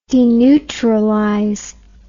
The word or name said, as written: deneutralization